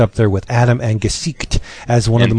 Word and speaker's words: Gesicht: I was calling his name Jessic. It's not. Let me try and get this right. – Gesicht.